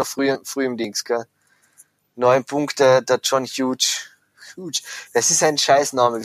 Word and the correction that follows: Hughes: Wieso kann er nicht Huge oder, wie spricht man den wirklich aus? – Hughes. – Hughes. Sagt der selber, ah, mhm. my name is John Hughes, weil man weiß es nicht genau, gell?